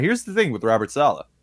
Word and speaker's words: Saleh: I've heard Saleh as well. I believe it's Robert Saleh. I'm not sure how he pronounces it. – It's kind of like a salad, you know? – Yeah.